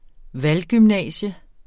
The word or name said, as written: valggymnasium